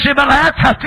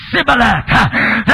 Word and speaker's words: Shibboleth: And they could not say Shibboleth. They say Sibboleth. They could not pronounce the letter H. The letter H was missing.